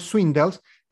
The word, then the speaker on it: Swindells: Este papel es interpretado por, Connor Swindells, perdón, lo dije mal, por Connor Swindles,